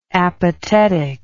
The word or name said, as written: apatetic